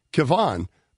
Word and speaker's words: Kevon: The Golden State Center's first name should be pronounced Kevon, but the Staples Center public announcer dresser hasn't caught on yet.